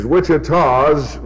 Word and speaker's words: Wichitas: because the plural of Wichita is Wichitas, which rhymes with was, and very few things in English do rhyme with was, you see.